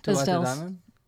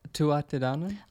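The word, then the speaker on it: Tuatha Dé Danann: We had a little bit of discussion about the pronunciation, didn't we, of the live track, Maeve and the Morrigan. Which was at Isdale. – Tuatidanen. Tuatidanen. – Ah, very good. Yeah. You got it right.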